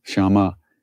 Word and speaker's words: Shyama: Gauri, Shyama Gauri, and Shyama, the long A at the end, Shyama, makes it the feminine, and it refers to Kali, the dark goddess, the dark-colored goddess.